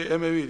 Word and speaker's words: Emevi: Arapçası Umevi'dir, Türkçe Emevi diye telaffuz ediliyor.